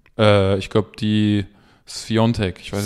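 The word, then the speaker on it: Świątek: Die Sviatek geschrieben wird, aber Sviontek, äh, Sviontek ausgesprochen wird, wie der ex-härter Fußballer äh, Piontek, der auch Piatek geschrieben wird.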